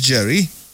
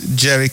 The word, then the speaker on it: Jerry: My name is Jerry, and surely you can always follow me on all socials, Jerry Can Odo, J-E-R-R-Y-C-A-N, Jerry Can Odo is spelled O-R-D-U-H.